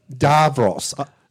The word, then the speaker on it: Davros: Davros. That's a revelation. I didn't realise that was actually the correct pronunciation.